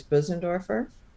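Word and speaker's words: Bösendorfer: It's the actual German pronunciation is Bussendorfer. – Bussendorfer, oh, okay. – Yeah, all with right. A brr from the umlaut over the O.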